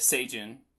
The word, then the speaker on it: Seijun: Yes, Seijun.